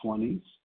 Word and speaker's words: Jaunice: This is Juanice N, and her name is spelled J-A-U-N-I-C-E, and it's pronounced Juanice.